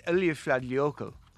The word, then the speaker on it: Eyjafjallajökull: It's Eyjafjallajökull. He's wrong. – No, he's keeping his Dublin accent. Mount Eyjafjallajökull.